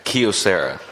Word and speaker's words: Kyocera: It's a K-Y-O-C-E-R-A copier.